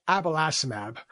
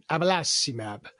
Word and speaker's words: abelacimab: It's called abelacimab, or we like to say it with an Italian version, abelacimab.